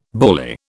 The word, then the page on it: bally: British English